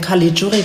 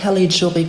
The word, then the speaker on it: Caligiuri: Ich bin total froh, dass du eben Caligiuri gesagt hast, weil der Reporter im ZDF hat das gestern wieder falsch gesagt. Ja. Der hat Caligiuri gesagt und ich finde das immer so schlimm, weil der spielt seit 100 Jahren in der Bundesliga und der ist nun mal kein US-Amerikaner, sondern der ist Italo-Schwabe.